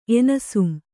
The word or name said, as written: ಎನಿಸುಂ